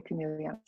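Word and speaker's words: Conegliano: Imoco Volley Corneliano.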